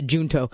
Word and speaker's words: Junto: I would pronounce it the Junto, but apparently they pronounce it the Junto.